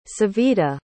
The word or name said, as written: savita